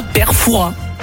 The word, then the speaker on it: Fouras: Eh bien écoutez, on dit Fouras. Ne prononce pas le S. – Et donc on dit bien Père Fouras.